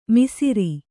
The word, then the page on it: ಮಿಸಿರಿ: misiri